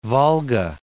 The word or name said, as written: Volga